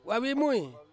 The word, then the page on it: vavimui: Ansus